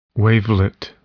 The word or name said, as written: wavelet